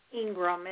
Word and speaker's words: Ingraham: So Faith Ingraham, and our last name is spelled Ingraham.